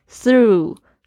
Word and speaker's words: through: 要注意一下你们的 through 的发音，th 这个发音不是很好发，它是轻轻咬住舌尖发出了这个气音 through。它的这个 g h 呃这个字呢是没有发音的。